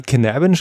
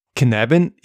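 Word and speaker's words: Cynefin: Dave Snowden ist also Waliser und Knabin ist ein walisisches Wort. Und da fallen Schreibweise und Aussprache für den gelernten Mitteleuropäer ein wenig auseinander.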